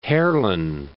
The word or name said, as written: Heerlen